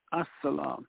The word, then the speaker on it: As-Salam: But we put the A-L, though it's pronounced A-S, but still saying A-L in front, we're saying the peace, As-Salam.